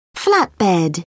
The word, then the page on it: flatbed: British English